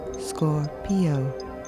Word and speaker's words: Scorpio: Scorpio, emphasis on the second syllable. Scorpio.